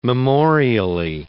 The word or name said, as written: memorially